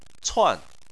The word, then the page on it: cuan: 4